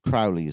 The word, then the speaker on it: Crowley: Aleister Crowley, I called him Crowley then. Crowley is the right one. Other people call him Crowley.